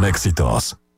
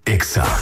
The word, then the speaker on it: Exa: No se dicen éxitos, se dice Exa.